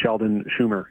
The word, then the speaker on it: Sheldon Chumir: There's an application in for the Sheldon Chumir Health Center. And sorry if I said that wrong. – No, you said it right.